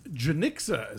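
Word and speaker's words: Janicza: And so I in that way, not only is it a sort of like a fun, fresh kind of filmmaking from Janisha Bravo, who made Lemon. – Janixa, as it turns out. I thought it was Janisha, too. – Janixa.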